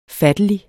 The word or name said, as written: fattelig